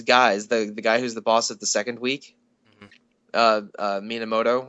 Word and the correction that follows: Minamimoto: or something, I might be getting his name wrong. Okay, I'm looking at a list now. Okay, Minamimoto. So I assume that we get all the names wrong. – I just forgot a syllable the first time.